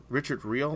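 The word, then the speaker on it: Richard Riehle: And Richard Reilly, how you say it. – Richard Reel.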